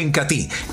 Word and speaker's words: Incatea: Entonces el Incati, se escribe Incatea, pero se pronuncia Incati,